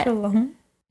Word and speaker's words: Shalom: Shalom? How do you say it? Is that right? – Shalom. – Shalom.